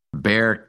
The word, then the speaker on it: Bexar: But anyway, he was running in Bexar County. It's called Bexar County, but it's spelled B-E-X-A-R. Don't ask me why they don't pronounce the X, but anyway, that's what it is.